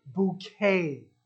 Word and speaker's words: Bucket: The sitcom delves into the life of a character by the name of Hyacinth Bucket, which she insists is pronounced as bouquet.